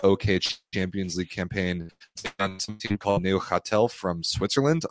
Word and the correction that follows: Neuchâtel: Neuchâtel. – Oh, so it's like French pronounced.